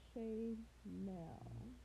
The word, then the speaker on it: Shaynell: My Instagram is officially underscore Shay Nell.